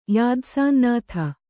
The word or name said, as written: ಯಾದಸಾಂನಾಥ